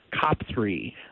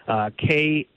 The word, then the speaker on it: Khepri: The name of that Egyptian god is, I'm probably going to butcher the name, but I believe it's pronounced Khepri, Khepri.